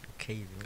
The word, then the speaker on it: Caven: Yeah, Kevin or Caven? – Caven. I'm quick.